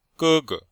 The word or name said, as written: ge1ge5